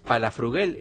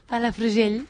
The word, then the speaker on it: Palafrugell: Estamos muy contentos de recibir recibirla a ella, que es de Palafrugell, ¿lo dije bien? – Sí. – ¿Se llama así tu pueblo? – Palafrugell. – Sí. Palafrugell, en Gerona.